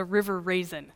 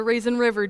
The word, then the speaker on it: River Raisin: I used to live in Monroe County, which is a couple of miles from the River Raisin. That's how they say it. It's not the Raisin River. Don't say the Raisin River.